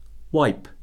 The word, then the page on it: wipe: verb